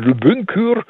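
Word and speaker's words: Lübünkür (Лабынкыр): Ich habe mir das sogar, es ist ein jakutisches Wort und ich habe also mir extra die Videos dazu angesehen, das wird wirklich so ausgesprochen, Lübünkür.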